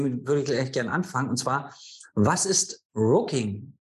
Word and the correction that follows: Rucking: wenn ich es richtig Rucking. ausspreche? – Rucking.